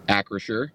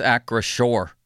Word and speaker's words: Acrisure: How do you say the name of the home stadium? – Acrisure. – You're wrong. It's Acrisure. – Acrisure? – Yeah, it's Acrisure Stadium. Acrisure.